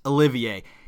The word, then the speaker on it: Olivier: And fuck me, I said that wrong. Sir Laurence Olivier.